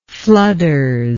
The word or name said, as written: flooders